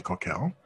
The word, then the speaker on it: Cockell: Right, Jenny Coquel, which is Jenny, and then Coquel is C-O-C-K-E-L-L, and it's pronounced like that, Jenny Coquel, because I believe it's French.